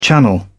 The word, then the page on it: channel: British English